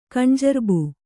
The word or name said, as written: ಕಣ್ಜರ್ಬು